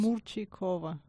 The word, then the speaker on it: Murchikova: Just Murchikova.